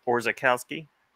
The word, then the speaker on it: Orzechowski: Orzechowski.